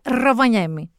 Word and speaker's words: Ροβανιέμι: Και δεν το λέω έτσι. Για κάποιον ανεξήγητο λόγο οι Φινλανδοί το προφέρουν σαν Ιταλοί. Λένε Ροβανιέμι.